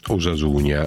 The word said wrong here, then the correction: Osasuna: Ha perfettamente ragione, si legge invece Osasuna senza il GN, e mi spiega che la ragione è semplice, perché questa è una parola basca che significa salute e va pronunciata in quella maniera, Osasuna.